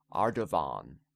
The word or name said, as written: Ardavan